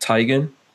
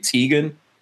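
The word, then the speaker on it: Tiegen: Because he tells me that you're supposed to pronounce it Tigan, but a lot of people say Tig Tegan.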